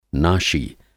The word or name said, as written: नाशी